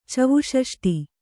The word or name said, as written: ಚವುಷಷ್ಟಿ